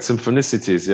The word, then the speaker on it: Symphonicities: I mean, obviously, you've done so much with Sting over the years, but when you did the Symphonicities, I hope I pronounced that correctly. I always struggle with that. – Yeah, totally correct. Symphonicities, yeah.